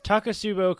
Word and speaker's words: Takotsubo: That's how you pronounce it, right, Charles? Is it Takotsubo? – There's the next t-shirt. – Yes. Right, the next there. T-shirt. Takotsubo cardiomyopathy.